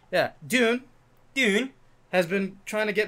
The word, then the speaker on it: Dune: It's either Dune... – June. – Yeah, some. It's nobody knows how to pronounce it. – I just say dune. – Yeah, like sand, sand dune. – Yeah. – No, it's like a sand dune.